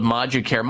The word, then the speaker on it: ModuCare: I want to make sure people know how to get a hold of ModuCare. ModuCare, by the way, is spelled M-O-D-U-Care, C-A-R-E.